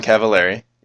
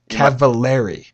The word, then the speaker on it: Cavallari: Oh, well, her name is Kristen Cavallari. – Cavallari.